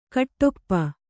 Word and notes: ಘಾಟುಪ್ಪು: Kannada

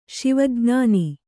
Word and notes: ಶಿವಜ್ಞಾನಿ: Kannada

